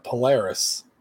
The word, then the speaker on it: Polaris: Polaris.